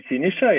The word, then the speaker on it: Siniša: So my name is Sinisa, yes, and I'm based in Belgrade and coming from Belgrade originally.